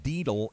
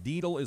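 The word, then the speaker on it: daedal: Deedle is the word today. Deedle is the word of the day.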